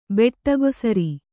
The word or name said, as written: ಬೆಟ್ಟಗೊಸರಿ